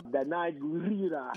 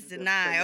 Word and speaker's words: Danai Gurira: Now, Danai, I don't want to say her name incorrectly, but Black Panther, hello. – Danai Gurira. – Yes, Danai. Okay, I was saying it correctly.